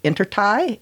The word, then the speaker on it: intertie: the water intertie. Is that how you say it? – That's right.